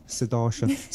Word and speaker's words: Sudarshan: How do I say that? – Sudarshan. – Been saying it wrong the whole time. Sudarshan.